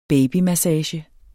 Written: babymassage

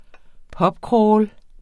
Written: pubcrawl

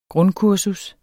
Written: grundkursus

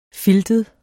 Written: filtet

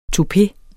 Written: TOUPE

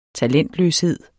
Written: talentløshed

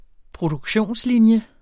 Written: Produktionslinje

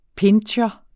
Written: pinscher

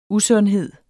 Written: usundhed

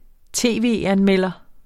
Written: tv-anmelder

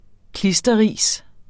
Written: klisterris

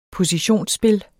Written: positionsspil

